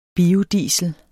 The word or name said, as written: biodiesel